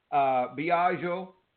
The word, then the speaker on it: Biagio: But anyway, and the book is written by Biagio. Bill is in the parentheses, and Biagio is spelled B-I-A-G-I-O. So Biagio, Bill, Sciacca, and you can find it on Amazon.